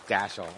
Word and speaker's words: Dashiel: Is that the right way to pronounce it? Dashiel? Dashiel, okay. So Dashiel, and congratulations to the beaming grandparents over here.